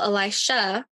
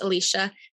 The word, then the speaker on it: Elisha: Well, Elisha, also commonly referred to as Elisha, I looked it up trying to figure out which is actually the correct pronunciation, and people pronounce it both ways. So I'm just going to stick with the one that it sounds in my audio Bible that I have access to, and it says Elisha.